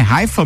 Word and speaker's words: Haifa: do time é Haifa mesmo? – É.